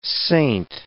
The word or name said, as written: saint